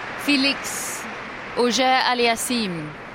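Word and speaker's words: Félix Auger-Aliassime: Felix Auger Aliassim.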